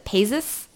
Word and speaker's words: Paizs: Anyway, so Crime Wave is directed and written by John, I think his last name, you pronounce it Paizis? – Mm-hmm. – P-A-I-Z-S.